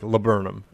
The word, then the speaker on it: laburnum: But also, like, the person who pronounced it, like, said it, like, they were like, laburnum. And it was like, okay, it's L-A-B-U-R-N-U-M, which is still very weird to me.